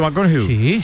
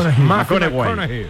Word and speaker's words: McConaughey: Mateo McConaughey. – Sí. – Hay que ir corrigiendo la pronunciación. – McConaughey. Mike McConaughey.